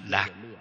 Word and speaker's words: nhạc: Nhạc thời cổ đại còn có một cách đọc là lạc, Chữ lạc trong tỷ lạc, tức là vui vẻ.